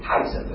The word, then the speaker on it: Caesar: Caesar 是英文，其实它的原来的发音就是凯撒，就是那个凯撒，